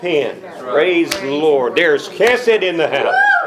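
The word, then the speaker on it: chesed (חסד): That first letter, the chet, is very hard to pronounce. But it's pronounced chesed.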